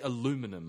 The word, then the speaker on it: aluminum: People literally aluminum. Cut out an I and say aluminum.